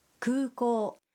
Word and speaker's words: 空港: 空港 는 공항이라는 뜻인데 구고의 우와 오는 길게 발음합니다.